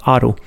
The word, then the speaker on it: Haro: Och här uppe i eh, Rioja Alta så ligger också en by som heter Aro. Alltså H-A-R-O, Haro, men uttalas Aro.